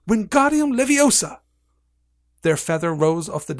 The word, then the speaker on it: Wingardium Leviosa: Wingardium Leviosa! he shouted, waving his long arms like a windmill. You're saying it wrong, Harry heard Hermione slap, snap. It's Wingardium Leviosa. Make the gar nice and long.